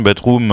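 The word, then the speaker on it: Bdrm: Bedroom, ça se prononce comme ça, mais en fait, comme se font assez souvent certains groupes, ils ont juste enlevé les voyelles pour tromper un peu l'ennemi. Et c'est comme ça qu'on prononce tout de même.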